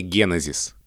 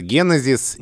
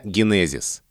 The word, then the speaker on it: генезис: Что вот мы можем, например, сотни раз говорить, что правильное ударение — генезис. Именно так, в соответствии с происхождением этого слова, рекомендуют произносить словари. И, например, в словаре Михаила Абрамовича Штудинера, поддержим традицию и упомянем Михаила Абрамовича в этом выпуске тоже, тоже говорится генезис, не генезис.